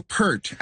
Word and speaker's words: Peart: I've been saying Neil Peart, as in pearl. – Yeah. – It's Peart.